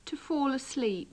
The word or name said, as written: to fall asleep